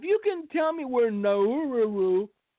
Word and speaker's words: Nauru: N-A-U-R-U, Nauru, Palau, Marshall Islands, Guatemala, and Honduras.